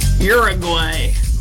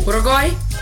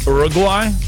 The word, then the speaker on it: Uruguay: Uruguay. Had to say it like American. – Yeah. Can you say it as a non-American? – Well, it doesn't start with a your sound. – Oh. – Uruguay? – Uruguay?